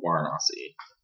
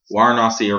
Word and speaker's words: Varanasi: So I went to a town called and for most of my visit there, I was in a town called Varanasi. Varanasi or Varanasi. It's spelled with a V, but then they pronounce it with a W because Hindi, I think Hindi or some other language.